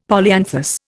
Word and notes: polyanthus: Indian

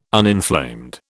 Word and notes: uninflamed: British English

